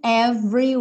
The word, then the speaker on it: every: Lucky we're in love in every way. Every. Lembra que esse é aí do meio tá pintadinho de cinza, então não vai ser pronunciado.